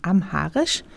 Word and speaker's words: amharisch: Und ist das, was sie singt, amarisch? oder amharisch oder wie spricht man? Ja, du bist sehr gut vorbereitet. Es gibt nämlich Leute, die sagen dann immer arabisch. Und ich muss dann sagen amharisch.